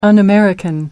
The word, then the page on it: un-American: American English